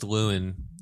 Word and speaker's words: Llewyn: She's also in Inside Lord Lawlen Davis? The Oscar Isaacs movie? – What? – Inside Lawlen Davis. I don't know how to say his name. – I think it's just Lewin Davis. L-L-E-W-Y-N.